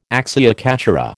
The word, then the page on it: acciaccatura: American English